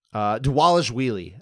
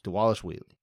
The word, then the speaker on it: Dvalishvili: Dvalishvili. I need to say his name right. I've been saying it wrong. Dvalishvili. The V's are pronounced like W's.